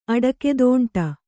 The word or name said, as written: ಅಡಕ್ಕೆದೋಂಟ